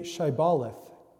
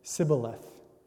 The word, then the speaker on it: Shibboleth: When he said, 'No,' they said, 'Then say Shibboleth.' And he said, 'Sibboleth,' for he could not pronounce it right.